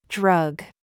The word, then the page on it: gig: American English